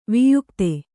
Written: ವ್ಯಕ್ತ

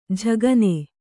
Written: ಝಗನೆ

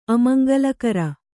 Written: ಅಮಂಗಳಕರ

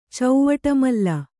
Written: ಚೌವಟಮಲ್ಲ

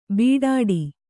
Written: ಬೀಡಾಡಿ

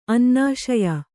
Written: ಅನ್ನಾಶಯ

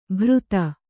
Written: ಭೃತ

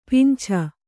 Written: ಪಿಂಛ

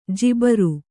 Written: ಜುಬ್ಬರ

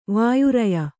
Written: ವಾಯುರಯ

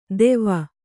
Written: ತೇವ

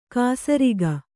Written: ಖಾಸರೋಗ

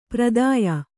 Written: ಪ್ರದೇಯ

